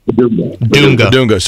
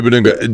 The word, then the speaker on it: Bidunga: It's Flory Badunga, right? Is how you say his last name? – Badunga. – Sorry. Flory Badunga.